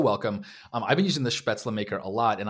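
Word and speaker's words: Spätzle: Spetzla, actually. I think because I think I said Spetzel, and then a bunch of people tweeted at me that it's Spetzla.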